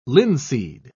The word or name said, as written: linseed